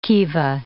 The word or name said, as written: kiva